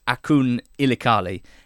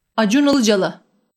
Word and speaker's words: Acun Ilıcalı: If you read his name in English, it's Akun Ilikali. If you listen to the YouTube video where with the title How to Pronounce That Name Correctly in Turkish, it sounds very different.